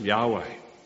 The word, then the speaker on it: Yahweh: pronounced Yahweh.